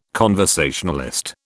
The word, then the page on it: conversationalist: British English